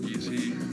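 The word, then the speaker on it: Jiří: Es Easy. Y se escribe Giri. – Giri, pero es... – J-I-R-I. Y suena como... – Easy.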